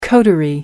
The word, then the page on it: coterie: American English